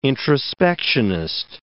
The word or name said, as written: introspectionist